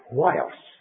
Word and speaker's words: huios: And then when we come to the New Testament, we have a word which is rather difficult to pronounce in English, because it starts with the letter H and the vowel U-I-O. The only way we can pronounce it is huios.